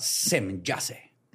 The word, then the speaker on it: Semjase: Dijo que su nombre era Semjase, con J, pero se pronunciaba Semjase.